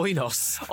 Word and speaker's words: Oinos: I feel like also, like, going back to I'm gonna say its name wrong now, Onion. Onion. So not onion. – Oinos.